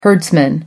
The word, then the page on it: herdsman: American English